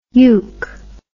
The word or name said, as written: yuke